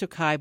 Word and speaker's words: Tokaji: And it's pronounced Tokaj, but it's spelled Tokaji. Tokaji.